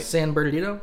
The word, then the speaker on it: San Bernardino: This is near San Bernardino County. Am I pronouncing that right? – San Bernardino? – Yeah, Bernardino.